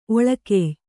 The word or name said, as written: ಒಳಕೆಯ್